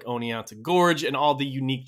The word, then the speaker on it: Oneonta: Now, one last thing, getting back to that pronunciation of whether it's Oneonta or Oneonta. Since Watkins named the gorge for his hometown in New York, I just went ahead and called up the city in question. I talked to a lovely woman at reception who worked for the city council, and she assured me that the town's name is pronounced Oneonta with the T.